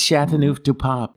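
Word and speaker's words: Châteauneuf-du-Pape: Which, by the way, ladies and gentlemen, most people can't pronounce Chateauneuf-du-Pape, but you can pronounce GSM. Grenache. – Yes. – Syrah. Mourvèdre. They're making a lot of that in California, gang. It's Chateauneuf-du-Pape. That's what it is.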